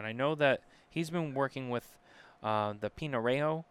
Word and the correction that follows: Pinareño: Pinareno. I already forget how you how you say it. Um, tobacco. – he's talking about Pinareño,